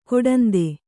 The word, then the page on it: ಕೊಡಂದೆ: koḍande